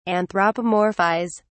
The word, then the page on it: anthropomorphise: British English